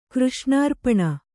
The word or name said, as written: ಕೃಷ್ಣಾರ್ಪಣ